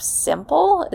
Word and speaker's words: Symple: Or I've heard really good things about the app Simple. It's spelled S-Y-M-P-L-E,